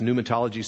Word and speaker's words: Pneumatology: Pneumatology spelled with a P, P N E U M A T O L O G Y and uh,